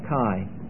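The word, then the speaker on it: Kai (καί): Just K-A-I, spelled Kai. Spelled K-A-I, pronounced Kai.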